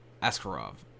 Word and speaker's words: Askarov: Moreno was cut from the UFC back in 2018, made his comeback earlier last year, draw to Askarov, is that how you say his name? Yeah, Askarov. I did not spell that right on my notes here.